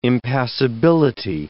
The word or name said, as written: impassibility